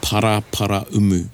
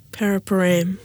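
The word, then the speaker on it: Paraparaumu: So it's para para umu. – Yes. – Not paraparam. – Not paraparam.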